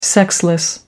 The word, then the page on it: sexless: American English